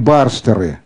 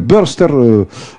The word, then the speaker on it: burster: Может быть, некоторые из вас это слово уже слышали, но это искаженное, так сказать, нижегородское произношение, так сказать, английского слова burst, вспышка. Англичане говорят burster, мы говорим барстер, но по сути это одно и то же.